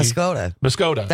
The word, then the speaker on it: Muscoda: I'm so glad I work here because I would have never known how to pronounce Muscadet because I pronounced it, I think, as like Muscogee. – Muscoda. That's how I pronounce it.